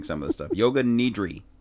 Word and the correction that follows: nidra: Nidra. Banish. – You got it.